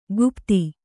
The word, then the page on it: ಕುಪತಿ: kupati